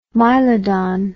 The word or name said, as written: mylodon